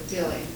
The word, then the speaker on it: Daly: and I don't know how to pronounce his name, Daly or Daly? – Daly, Herman Daly.